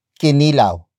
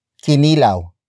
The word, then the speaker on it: kinilaw: It is the focus marker that comes before the main noun of the sentence which in this case is kinilaw, kinilaw, kinilaw. Kinilaw is spelled as K I N I L A W.